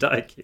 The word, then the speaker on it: Nike: no, we pronounce it Nike.